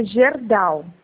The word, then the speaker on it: Gerdau: Cagerdal, ele tem convênio com Cagerdal. – Eu vou verificar se tem convênio com essa empresa. Só me auxilia na pronúncia, como que é? – É Gerdau.